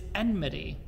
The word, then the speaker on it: enmity: Enmity, which is very much different from how my brain wants to say it, which is enmity. Enmity, enmity. It's like the word realtor. Anyways, enmity.